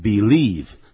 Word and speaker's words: belief: 这个时候呢， belief 是名词，它是这样拼的， B-E-L-I-E-F B-E-L-I-E-F Belief. – It is their belief. 我刚念的是 belief, 马上念成 believe, 其实两个都可以。